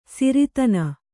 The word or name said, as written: ಸಿರಿದಿನ